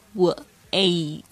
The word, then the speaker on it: wait: Wait, 特别注意一下这个 wait, 这个 A-I 的发音是这个 A, 是双元音。你不仅要发 A, 还要把 E 发出来。那重点是在这个 A 上面。 A, wait, wait.